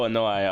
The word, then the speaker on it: Anoa'i: Learned that Roman Reigns, well, Joe Anai, a.k.a. Roman Reigns. – That's how you say his name? – Anai or Anoa'i? I think that's how it is, yeah. So Joe Anoa'i.